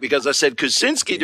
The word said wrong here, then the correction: Kuczynski: during Bellarmine, and we had a couple of fans come over and correct me on that.